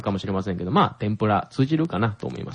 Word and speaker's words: 天ぷら: 天ぷら。 – 天ぷら。あ、綺麗な発音で言えましたね、うん、ごめんなさい、バカにするわけじゃないんですけど私がよく聞く外国人の天ぷらっていうのは例えば tempura とかですよね。